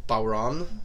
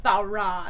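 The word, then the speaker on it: bodhrán: It is pronounced Bowron. – Bowron.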